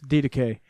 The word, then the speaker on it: Didache: Didache. Am I saying that correctly? – Yes, Didache.